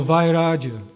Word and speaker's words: Vairaja: Oh, Vairaja. Yeah, that's the way it's written here. Vairaja. V-A-I-R, long A-J-A.